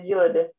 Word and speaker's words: Yod: It's not the same as Yod. – Yeah, Yod. It, Yod's a Yod's just a modern Hebrew pronunciation.